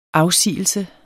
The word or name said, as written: afsigelse